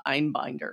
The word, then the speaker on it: Einbinder: played by Hannah Einbinder.